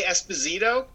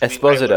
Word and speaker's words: Esposito: We always say Esposito. It's not how it's pronounced. – Esposito.